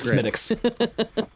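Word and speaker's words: Smithwick's: No, nothing's hotter than the Smithix. As long as you don't pronounce the THW.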